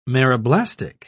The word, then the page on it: meroblastic: British English